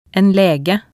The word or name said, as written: en lege